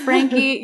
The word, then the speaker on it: Franke: It's just Natalie Franke, and Franke has an E at the end. It's spelled like you would never imagine it, but that's okay. – Frankie. So it's just Frankie.